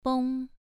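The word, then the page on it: beng: tone 2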